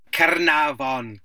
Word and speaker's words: Caernarfon: Caernarfon Castle. You put some effort into the Welsh pronunciation, but Caernarfon, yeah.